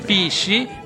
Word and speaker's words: PSSI: Si pronuncia PSI.